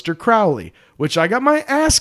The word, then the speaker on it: Crowley: But my friends say Crowley for they know I am holy.